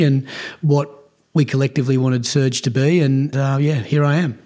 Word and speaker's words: SRJ: Moving from the A-Leagues to, is it Surge? Is that the correct pronunciation? – It is, yeah, Surge Sports Investments.